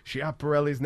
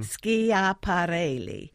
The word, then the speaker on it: Schiaparelli: Well, if you were in Rome, you would pronounce her name with five syllables, mm-hmm. Schiaparelli. But if you say Schiaparelli, that's just fine.